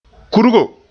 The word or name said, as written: kurgo